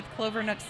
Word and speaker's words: Clovernook: We have our community, we have our educational platform, and then we've recently partnered with Clovernook Center for the Blind. – I said it right. Did I say it right? – You did say it right.